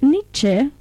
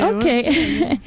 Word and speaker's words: Nietzsche: And yes, so it has a very modernistic undertone, and it's thought that Friedrich Nietzsche, how do you pronounce Nietzsche? – That's perfect. – Nietzsche. Okay.